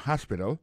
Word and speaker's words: hospital: L'hôpital, c'est comme ça, on dit ça en anglais, hospital. Alors, accent tombe sur premier syllabe là, il y a trois syllabes, puis she likes to visit hospital. Ensuite, elle a écrit H-O-S-P-I-T-A-L, hospital, l'hôpital.